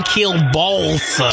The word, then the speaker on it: kielbasa: So I've really dishonored the ancestors by not saying kielbasa properly. All right. It's the L. It throws me off.